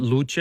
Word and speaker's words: Luce: Now, this electric Ferrari, it's called the Luce. Am I pronouncing that correctly? – Luce, exactly. Luce. It's Italian for light.